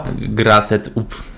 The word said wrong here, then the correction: Gassed Up: Czy tam jest R? – A nie ma. Gassed Up. Gassed Up. Tak się montaż nazywa.